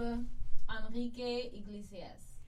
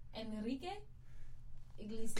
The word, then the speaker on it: Enrique Iglesias: I love Enrique Iglesias. – You didn't even say his name correctly. – Oh, how do you say it? – Say it again. – Enrique Iglesias. – Close enough. Yeah, close enough.